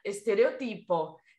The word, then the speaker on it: estereotipo: Então, e o tema de hoje é estereótipos, que em espanhol se pronuncia estereotipo.